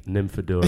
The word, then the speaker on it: Nymphadora: Nymphadora.